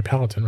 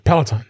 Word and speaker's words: Peloton: People just, with the video and everything on the, I'm saying that right, Peloton, right? – Peloton, that's it.